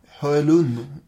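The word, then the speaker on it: Hoelun: Hans mamma, eh, hur ska man uttala det här då? Hulun. – Det tycker jag låter jättebra. – Hölun. Ja, ah, ännu bättre.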